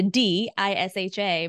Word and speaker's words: Disha: It's like five letters, but it's written with a D, I-S-H-A, but it's pronounced like a T-H. So it's Disha.